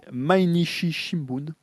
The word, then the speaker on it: Mainichi Shimbun: Mainichi Shimbun.